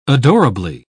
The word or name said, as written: adorably